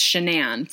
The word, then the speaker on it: Shanann: I've only ever read it, so I always just assumed it was Shannon, but I didn't realize it was looks like Shannon. but I believe that it's Shanann from everything I've heard kind of on the news and what I've heard other people pronounce it as.